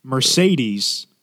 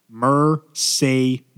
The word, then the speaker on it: Mercedes: Mercedes. – Oh, man. – Has 3 E's, which are all pronounced differently. Mercedes.